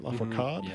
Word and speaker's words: Lafourcade: We're going to hear Soledad y El Mar from Natalia Lafourcade. Is that right? Lafourcade? – Yeah.